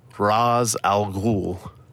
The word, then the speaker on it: Ra's al Ghul: So it's the Arabic pronunciation is Ra's al Ghul. It's a very strange way to say it when you're an English speaker. The word Ra's means something completely different than head or chief of the demon. So it is pronounced Ra's.